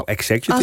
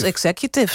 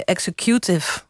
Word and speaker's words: executive: Want de vraag is, hoe spreek je het woord executive uit? – Nou, executive. – Als executive, zou je zeggen. Maar dat deed ik niet. Ik zei executive. ik weet eigenlijk ook niet waarom.